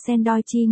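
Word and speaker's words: sandoichi (サンドイッチ): Shiruchi cười lớn, Sandowichi, nghe cứ như sandwich ấy nhỉ, trong tiếng Nhật bánh sandwich phát âm là sandoichi nghe giống với tên của Shiruchi.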